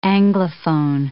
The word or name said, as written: anglophone